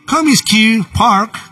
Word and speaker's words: Comiskey Park: se disputó en el Comiskey Park.